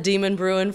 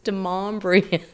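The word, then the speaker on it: Demonbreun: I said Demon Bruin for a long time. And finally I'm like, oh, wait, it's Demombrian. Yeah. – Pronouncing Demombrian has long separated the natives from the newbies.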